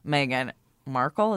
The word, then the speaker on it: Meghan Markle: Not only was this guy mansplaining about, like, a female minority experience, but he was mansplaining about your girl, Meghan Markle. Is that how you say it? – Yeah, Prince Harry's fiance.